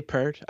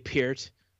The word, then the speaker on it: Peart: I like that you say his name properly, because I hear a lot of people that are just saying Neil Peart. – Yeah, some people say Peart. I say Peart.